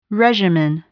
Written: regimen

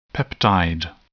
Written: peptide

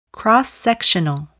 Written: cross-sectional

